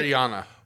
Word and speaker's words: Rihanna: Rihanna.